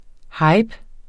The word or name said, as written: hype